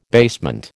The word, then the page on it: basement: American English